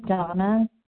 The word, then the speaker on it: Dana: This is Donna B., recovered compulsive overeater and bulimic, calling from Denver, Colorado. My name is spelled D-A-N-A, but it is pronounced Donna.